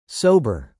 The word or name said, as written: sober